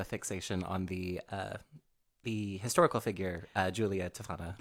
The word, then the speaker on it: Giulia: Which is good that we talked about this before we went on the air, because it's spelled G-I-U-L-I-A, which I was going to say Gilia. But it's Julia, the Poison Queen of Palermo.